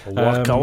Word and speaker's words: Oaxaca: Predominantly, it's made in Oaxaca, which is spelt O-A-X-A-C-A. So it doesn't look like it sounds. Oaxaca. – Waka, waka, waka. – Oaxaca, Oaxaca, Oaxaca.